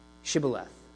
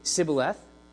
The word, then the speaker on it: Shibboleth: And so their identification of the Ephraimites, their enemies, it comes down to forcing everyone who wants to cross the Jordan River to pronounce a word, Shibboleth. If they're to pronounce the word incorrectly, as Ephraimites were wont to do, they would say Sibboleth.